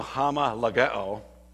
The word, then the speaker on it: homologeo: We in seminary, we always pronounce it hama. Just because we keep in the omicrons and the omegas separate, okay? Omicron and omega. So hama lageo.